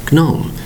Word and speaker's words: gnome: He is called a gnome, or a gnome, spelled G-N-O-M-E. The G is normally silent. It's pronounced gnome. Notice the spelling, G-N-O-M-E, one syllable.